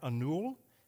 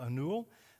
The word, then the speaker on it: Anil: The young man, some of you were in here and you heard that story about Anul. It's spelled A-N-I-L, Anul.